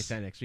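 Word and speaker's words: Fénix: Ray Fenix. We got to say his name. We got to put some respect on his name. – Because it's F-E-N-I-X. – Yeah. I can't roll my R's, so I got to say the last name properly.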